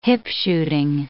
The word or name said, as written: hip-shooting